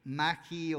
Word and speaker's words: magi: porque la palabra en el griego se escribe M-A-G-I, magi o magi, es en el griego,